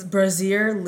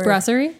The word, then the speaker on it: Brasserie: It's Brasier Lip. – Great. – Brasier. How do you say that word? – Brasserie.